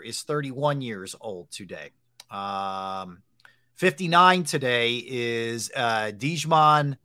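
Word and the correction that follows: Djimon: It's pronounced Jaman. – Jaman Hansu. – Hansu.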